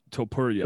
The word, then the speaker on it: Topuria: Ilya Topuria.